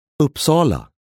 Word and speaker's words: Uppsala: Uppsala.